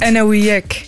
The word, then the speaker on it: Enauwiek: Ik zelf kan het natuurlijk niet perfect uitspreken. Spreek jij het even goed uit? – Enauwiek.